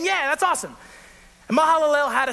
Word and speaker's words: Mahalalel: He named him Mahalalel. If you think I did take some Hebrew classes to learn this, I did look up on YouTube how to say this guy's name, okay? Mahalalel.